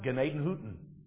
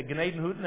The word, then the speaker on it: Gnadenhütten: and I learned how to pronounce it, Gnadenhütten. All right, I paid attention to how the Moravians said it. They said they moved into Gnadenhütten and Schoenbrunn,